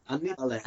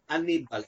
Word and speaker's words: Annibale: This recent album, Shambolic, was released through Annibale? Is that how you pronounce it? – Annibale. I'm the Italian one, so I know. – Right, exactly. – Annibale.